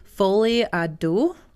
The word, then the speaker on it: folie à deux: I looked up everybody's names and made sure I was doing that correctly, but it's folie à deux. – Oh, I know what this is. – And it is a dual psychosis. – That, I think you pronounced it pretty right.